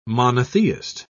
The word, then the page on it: monotheist: British English